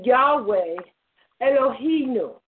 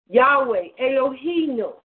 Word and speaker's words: Yahweh Elohim: Okay, see, this is why I went to go get the pronunciation. Okay, this is Yahweh Elohim. Thank you. Yahweh Elohim.